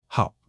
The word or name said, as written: hao3